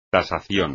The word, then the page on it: tasación: espagnol